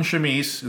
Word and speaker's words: chemise: The orangutan wore a white cotton chemise. Is that saying that right? C-H-E-M-I-S-E? – Yeah.